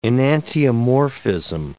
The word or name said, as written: enantiomorphism